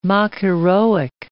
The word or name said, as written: mock-heroic